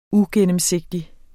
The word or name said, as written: uigennemsigtighed